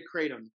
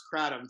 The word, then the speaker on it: Kratom: So then we got into Kratom, which you guys pronounced Kratom for the whole damn episode, which was great.